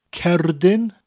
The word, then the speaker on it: cerdyn: It's spelt C-E-R-D-Y-N, the first word, and pronounced cerdyn. Although the first part of the word cerdyn sounds a lot like card, cerd, card, the R is pronounced differently. You need to roll that R in Welsh, whereas it's a lot softer in English. Card, cerd, that R, rolling R sound. Cerdyn. So we have cerdyn, followed by credyd, spelt C-R-E-D-Y-D.